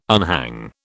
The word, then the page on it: unhang: British English